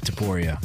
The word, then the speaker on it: Topuria: Guys, we had UFC 298 between Volkanovski and Topuria. Am I saying that right? – Yeah, Ilya Topuria.